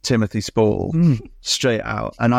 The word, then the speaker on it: Spall: Also, I feel like you picked a good actor there, because Spall rhymes with ball, call, you know.